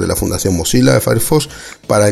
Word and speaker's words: Firefox: Firefox o Firefox, pronunciado Firefox.